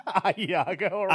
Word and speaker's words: ahegao: She's also doing how do you say it? Ahegao? – No. Ahiago. – Ahiago. She's doing that.